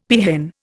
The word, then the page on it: peahen: Indian